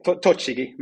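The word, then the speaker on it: Tochigi: Ja, det beror på vem man frågar, men en som gick viral minst sagt, och det var ju i Japan, Avispa Fukuoka, du får gärna rätta mitt uttal där, när du satte målet, om jag nu har tillskattat mig namnet rätt, mot Toshigi. Stämmer det bra? – Ja, ungefär kan man säga, men ja, precis. Toshigi, men i Japan hade jag i ordningen tre mål på frispark på två säsonger, så att där var jag vass.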